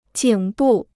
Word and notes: 颈部: jǐng bù

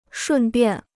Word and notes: 顺便: shùn biàn

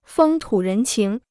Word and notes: 风土人情: fēng tǔ rén qíng

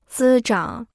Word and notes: 滋长: zī zhǎng

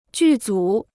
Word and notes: 剧组: jù zǔ

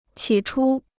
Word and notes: 起初: qǐ chū

